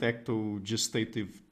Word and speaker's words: ectogestative: So first of all, and I hope I'm pronouncing this correctly, what are ectogenitive and ectogestative technologies? I mean, what distinguishes them and what are the sorts of questions that they bring to the table? – Yeah, thank you. No, you pronounce them perfectly.